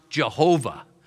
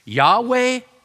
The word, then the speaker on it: Yahweh: That's what they would see, Yahweh. And sometimes they thought that was pronounced Jehovah, Yahweh or Jehovah, depending on how you pronounce it.